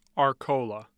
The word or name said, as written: arcola